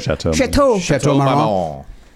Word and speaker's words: Chateau Marmont: I had the pleasure of interviewing him once at the GQ Man of the Year party at the Chateau Marmont. Am I saying that right? – Chateau Marmont.